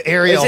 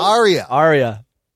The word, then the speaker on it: Arya: Ariel. – Am I saying it wrong? – Arya.